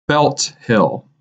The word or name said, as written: Belt Hill